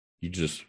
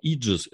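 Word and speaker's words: Aegis: Иджис, это, кстати, я не так давно с удивлением осознал. Иджис, это, оказывается, эгида, по-английски так произносится.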